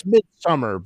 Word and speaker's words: Midsommar: It's Midsommar, but it's spelled with an O.